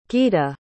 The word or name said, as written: guida